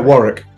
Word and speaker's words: Warwick: So Tina Kiefer from University of Warwick, and did I say that right? I never say it. – Yeah, Warwick. It's Warwick.